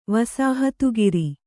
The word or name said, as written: ವಸಾಹತುಗಾರ